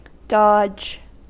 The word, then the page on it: dodge: American English